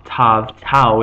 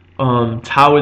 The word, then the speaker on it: Tau: Tau is pronounced as a T, a regular T, and without the dagesh, it is pronounced a TH, a th, not a hard the as in dalet, but a th as in things, the word things. It's pronounced as a soft TH sound.